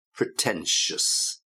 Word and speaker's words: pretentious: arrogant, thick, patronizing, obnoxious, and remember the pronunciation obnoxious, pretentious, and another one you have to be careful with the pronunciation, pretentious, ruthless, and then down to earth. So again, the two for the pronunciation there, obnoxious, pretentious. You got the same sound at the end, shus, obnoxious, pretentious.